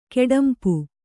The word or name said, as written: ಕೆಡಂಪು